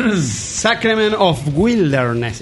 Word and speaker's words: Sacrament of Wilderness: Sacrament of Wilderness.